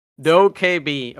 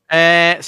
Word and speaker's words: Dokkaebi: I put the official pronunciation. This is from like Dokkaebi. All right. Yeah, perfect. Dokkaebi.